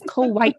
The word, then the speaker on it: Kauai: A lot of times people will pronounce this island Kauai, and guys, in the Hawaiian tongue, every vowel is enunciated. So it is Kauai.